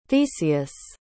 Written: theseus

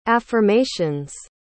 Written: affirmation's

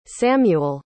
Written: samual

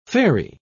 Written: fairy

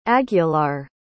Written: aguilar

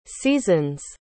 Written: season's

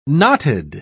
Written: knotted

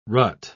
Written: rut

